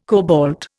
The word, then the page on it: cobalt: Indian